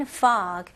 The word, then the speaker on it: fog: 이거는 발음을 F 발음으로 하셔야죠. 그래서 fog라고 하시면 안 되고 입술이 붙으면 안 돼요. fog. 그리고 G 발음을 명확히 잘 해주셔야 되고요. 여기서는 이 O 발음, 이거는 입술이 동그래지면 안 되고요. 이게 턱이 내려가는 느낌으로 fog. fog 이렇게 나와야 됩니다. 그리고 모음이 살짝 길면 더 좋고요.